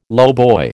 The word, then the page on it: lowboy: American English